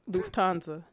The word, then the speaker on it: Lufthansa: Lufthansa? – Lufthansa.